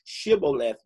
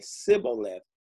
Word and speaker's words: Shibboleth: They said, say, Shibboleth, but because they couldn't pronounce it, instead of saying Shibboleth, they said Sibboleth.